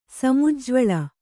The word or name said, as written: ಸಮುಜ್ಜ್ವಳ